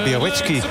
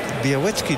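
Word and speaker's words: Białecki: Biarritzky. – Ja, je hebt een L met een streepje erdoor. Het, ja? Die moet je dan als een W uitspreken. – Ah, oké. Biarritzky dus.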